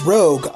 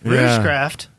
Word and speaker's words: Rogue: That's Rogue, R-O-G-U-E, by the players, for the players. – Wow, Rouge. Fancy. – Roogecraft. – Roogecraft. Yeah. And they probably knew we'd say it wrong, right?